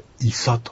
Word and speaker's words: イサ: はい、この医者というのもですね、本当は日本語ですけども、アイヌ語に取り入れられて医者と発音できないのでイサというふうに発音されております。ですからこれは日本語からアイヌ語に取り入れられたアイヌ語というふうに言います。